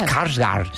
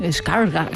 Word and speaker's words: Skarsgård: Skarsgård. – Sí, Skarsgård. – Es como pronunciarlo como Dumbledore de Harry Potter. Señor Skarsgård. Es un poco así.